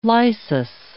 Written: lysis

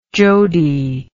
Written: jody

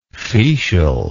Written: fetial

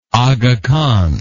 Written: Aga Khan

